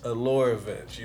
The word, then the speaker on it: Allure Events: She was like, it's Allure Events.